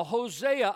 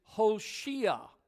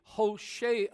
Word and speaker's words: Hoshea: A lot of times we say Hosea. The actual Hebrew rendering of this is Hoshea, is how you say his name.